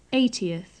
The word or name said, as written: eightieth